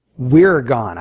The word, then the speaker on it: wyrgan: The word worry comes from an old English word, and it's spelled W-Y-R-G-A-N. Wirgan, I suppose, is how you would pronounce this.